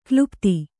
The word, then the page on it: ಕ್ಲುಪ್ತಿ: klupti